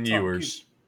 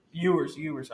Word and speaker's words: Ewers: Ewing? Is that starting? Quinn Ewers. – Ewers. Okay. I would love to watch that game again with Ewers in the entire time.